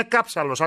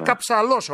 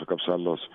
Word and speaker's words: Καψαλός: Νόμιζα ότι είναι Κάψαλος. Άκουγα κάτι φίλους. Ναι, είναι, ναι, Καψαλός όμως, ε. – Ναι, είναι ο Σπύρος ο Καψαλός.